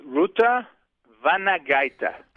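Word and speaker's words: Rūta Vanagaitė: I am having difficulty pronouncing the name of your co-author. Could you share that with us? – Sure. It's Ruta Vanagaita. – How did you meet Ruta Vanagaita? – Oh, that's an interesting story.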